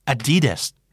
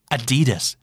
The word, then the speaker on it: Adidas: Adidas นะครับ Adidas เน้นตรง D นะฮะ Adidas ก็คือ Adidas นั่นเองนะครับมอตโต้ของ Adidas คือ Impossible is nothing Impossible is nothing อาจจะฟังดูแปลกๆนะเพราะว่าเราน่าจะคุ้นเคยกับคำกล่าวที่ว่า Nothing is impossible มากกว่าใช่ไหมฮะไม่มีอะไรที่เป็นไปไม่ได้ผมก็เลยไปค้นมานะครับเพราะว่างงเหมือนกันกับมอตโต้ของ Adidas